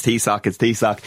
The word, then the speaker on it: Taoiseach: It's Taoiseach.